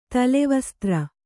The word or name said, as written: ತಲೆವಸ್ತ್ರ